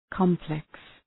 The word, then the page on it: complex: ‘kɒmpleks